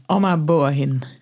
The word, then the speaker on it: Ama Boahene: Spreek je haar naam goed uit, Amma? Amma Boahin. Ah, Amma Boahin.